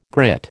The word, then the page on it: grit: American English